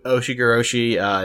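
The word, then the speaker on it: Ushigoroshi: You mentioned it, like the moonsault into the super kick, into the Oshigiroshi neckbreaker. Am I saying that correctly? – Yes, you are.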